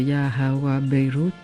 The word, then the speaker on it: Ya Hawa Beirut: Alors, je vais le prononcer à la française et vous me corrigerez. C'est Ya Hawa Beirut. Ça va ? – Bien. Pas de correction.